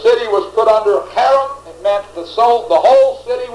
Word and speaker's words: cherem: It's the word cherem, C-H-E-R-E-M, cherem, pronounced with a cherem.